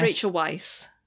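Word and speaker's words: Rachel Weisz: Rachel Weiss. – Rachel Weiss. Weiss. Yeah. – Weiss, as in, like, because it's spelt like the, it's white in German.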